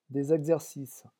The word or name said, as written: des exercices